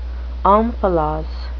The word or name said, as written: omphalos